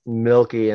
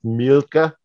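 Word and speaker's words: Milke: Well, people called me anything milk adjacent for my entire life, just because my last name is Milky, and it's like it's an Americanization of a German name, which is pronounced Milka, but who's going to pronounce Milka everywhere you go, right? So it just turned into Milky because the last letter is an E.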